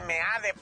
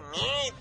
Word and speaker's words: Meade: O uno le dicen Meade, pero hay que decirle Mid. Así es como se debe pronunciar el apellido de nuestro próximo presidente.